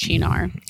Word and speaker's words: Cynar: It's spelled like C-Y-N-A-R. – Right. Yeah. – But it's okay. Pronounced Chinar.